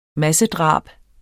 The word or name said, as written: massedrab